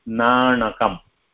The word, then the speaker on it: नाणकम्: So the first letter N is like we pronounce it while touching our tongue to the upper jaw, N. And N is a little bit behind the jaw, N. So it becomes N, N, Nanakam.